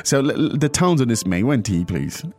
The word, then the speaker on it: 没问题: Now, 没问题 is something that you hear all the time.